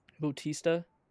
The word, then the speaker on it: Bautista: Bautista.